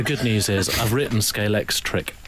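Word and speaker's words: Scalextric: I was a bit worried about the pronunciation of Scalex. Well, is it Scalex trick, I think, isn't it? – Yeah. – And you made a common error. A lot of people put X at the end.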